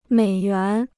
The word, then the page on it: 美元: měi yuán